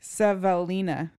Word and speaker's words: Savelina: Svenila. – I feel like I pronounce it different every time I read it. – Here I have it close by. Savalina. Savalina. – Savalina. – S-A-V-E-L-I-N-A.